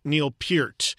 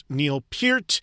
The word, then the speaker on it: Neil Peart: And yes, that is how you properly say his last name. I cannot tell you how many times I've heard people continue to mispronounce his last name, even in the weeks since we've lost him. His name is and was properly pronounced Neil Peart.